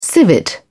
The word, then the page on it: civet: British English